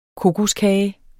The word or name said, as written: kokoskage